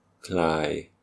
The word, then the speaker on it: klai: Klai. – Klai. – Klai. So the first one short, the second one long.